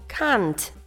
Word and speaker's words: can't: у British English це звучить як can't. – Can't. – А в American English ми скажемо can't.